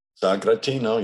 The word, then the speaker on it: Sagrantino: I hope I say this correctly. A Sagrantino. – Sagratino, yes, that's Sagratino.